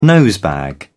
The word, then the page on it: nosebag: British English